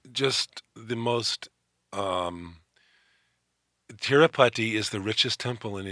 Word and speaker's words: Tirupati: And I guess the difference between American culture and Indian culture is exemplified by the intense energy at the temple at Tirupati. Is that how you pronounce it? – Yes, Tirupati.